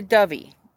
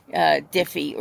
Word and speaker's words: Dyfi: And the book is called Death on the Dovey. Now, it says death on the D-Y-F-I. And this is a Welsh word, D-Y-F-I.